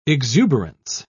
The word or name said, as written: exuberance